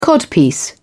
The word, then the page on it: codpiece: British English